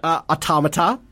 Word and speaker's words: Automata: Automata. I said it right this time.